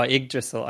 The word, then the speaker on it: Yggdrasil: So he's trying to find himself around this world, and he realizes that it's very similar to Yggdrasil, or however you pronounce it. And just make it an eye, friend. Just make it an eye at the beginning. – Yeah. Oh, Yggdrasil.